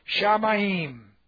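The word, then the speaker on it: Shamayim (שמים): This is Shah, S-H right here, S-H-A. And then we have Ma-Yim. Pronounce that way. Shamayim.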